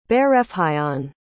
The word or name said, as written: bairrfhionn